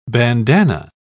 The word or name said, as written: bandana